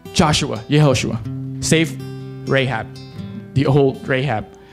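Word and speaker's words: Yehoshua (יהושע): Now, interestingly, these two names, Joshua and Jesus, are pronounced in Hebrew Yehoshua. Yehoshua. The same.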